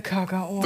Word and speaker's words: Kakao: der Badener, sagt Kakao. – Der sagt Kakao. Mit der Kakao. – Betonung, ganz wichtig.